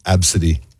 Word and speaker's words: Abcde: A-B-C-D-E. – Uh-huh. Absidy. A-B-C-D-E. That's how you spell it. A-B-C-D-E.